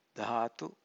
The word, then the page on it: dhatu: Pali